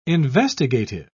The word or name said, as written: investigative